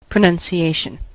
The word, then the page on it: pronunciation: American English